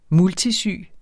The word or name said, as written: multisyg